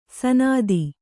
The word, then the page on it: ಸನಾದಿ: sanādi